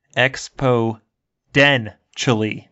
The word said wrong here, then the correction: exponentially: my entire fucking life. Exponentially. – I thought there was a den, not a nen.